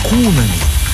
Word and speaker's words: куна: А когда славянские народы познакомились с западноевропейскими и арабскими серебряными монетами, то назвали их кунами в честь пушистого зверька. В словаре Дали есть 2 слова «куна» с ударением на первый слог.